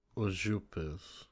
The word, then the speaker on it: Užupis: Ah, Scott, I found a website. Uzupis.